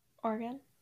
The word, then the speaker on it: Oregon: Oregon. Yeah, I think so. Yeah, it's not Oregon. – They make fun of people who say Oregon, right? – Yeah, so it's Oregon.